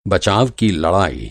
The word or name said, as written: बचाव